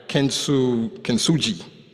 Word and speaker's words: Kintsugi: And this is called, pronounce it right, Kintsugi.